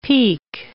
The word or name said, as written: pique